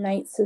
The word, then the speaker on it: Knights: and Knights is pronounced K, with a K.